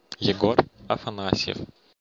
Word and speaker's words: Egor Afanasyev: Egor Afanasyev. – And now you see how it's different. First off, he says it's Egor, and he said that pretty forcefully.